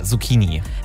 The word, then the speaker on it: Zucchini: Also spricht man das mit K oder mit R? Zucchini oder Zucchini? – Ja, mit K, Zucchini.